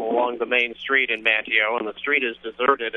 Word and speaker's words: Manteo: Actually, it's Manteo, North Carolina.